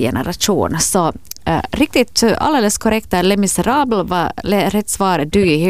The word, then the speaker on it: Les Misérables: Det är ju med lite reservation för min franska uttal. Les Misérables. – Det var fantastiskt uttal. Mycket bra. Les Misérables. – Les Misérables. Alldeles klockrent.